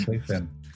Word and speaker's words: Pfeiffer: Todo por Michelle Pfeiffer. – La pregunta, aquí hay varias preguntas. ¿Cómo se pronuncia Michelle Pfeiffer? – o sea, Pfeiffer. – Claro. – Pfeiffer.